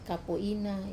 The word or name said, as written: kapo ina